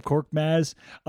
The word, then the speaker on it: Korkmaz: Korkmaz.